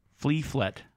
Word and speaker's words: Fliflet: Flea. Flea. Like a flea. – Yeah. – And then Flet. – Fliflet. – Yeah. – But it's spelled F-L-I. Fliflet.